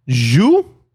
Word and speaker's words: jus: Jus? That's how you say it? – How would you say that? – Zoo. – Z-H-U, dude. Jus.